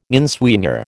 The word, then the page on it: inswinger: American English